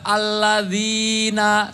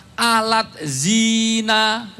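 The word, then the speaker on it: Alladzina (الذين): Kalau kita nggak tahu bahasa Arab, bacanya pakai transliterasi bahasa Indonesia. Alat zina. – Jauh amat dari kalau banget. Aladzina itu kalau di dalam bahasa Indonesia ditulis alat zina, malah jadi kontrasepsi.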